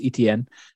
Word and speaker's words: Etienne: It's pronounced Etienne. – Uh, Etienne, because um, it's the French is, place.